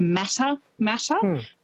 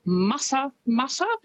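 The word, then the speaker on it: Matamata: Quite often, because of how it's spelt, people will pronounce it Mata Mata, but it's Mata Mata, which is, I think, a lovely way to say it, and Tauranga as well as actually Tauranga.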